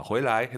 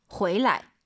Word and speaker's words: 回来: 근데 어렵게 말씀을 드리면 回 뒤에 있는 라이나 취는요. 경성입니다. 방향보호로서. 돌아오다. 回来 해도 되지만 정확한 발음은 回来.